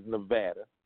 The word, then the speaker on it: Nevada: I'm saying, I'm submitting to you that because Nevada, and I did say Nevada, not Nevada, just to give, for those of you who are not aware of it, the proper pronunciation is Nevada.